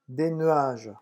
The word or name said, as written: des nuages